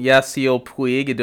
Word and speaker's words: Yasiel Puig: What was the first thing that Yasiel Yasiel Puig? Am I saying his name right? Uh, yes.